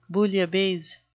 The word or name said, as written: bouillabaisse